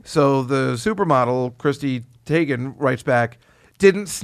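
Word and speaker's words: Teigen: So then this Sports Illustrated model, Christine Tagan, do you know? Did I pronounce that correctly? – Yes.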